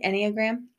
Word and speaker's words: Enneagram: So it comes from the, am I saying that right? Enneagram? – I think it's Enneagram. That's how I've been saying it.